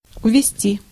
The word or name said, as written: withdraw